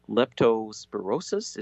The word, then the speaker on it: leptospirosis: apparently they get a disease called leptospirosis, is that my pronunciation? Yeah.